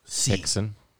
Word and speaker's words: Rickson: Oh, it's Hickson Gracie. Okay. – Yeah. R-I-K. – I thought it was a brother R-I-C-K. I didn't know. – It's spelt with an R because of the Portuguese.